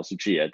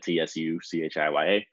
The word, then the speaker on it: Tsuchiya: It's Kyle Tsuchiya, Tsuchiya.